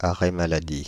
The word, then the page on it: arrêt maladie: France (Île-de-France)